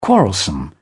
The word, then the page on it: quarrelsome: British English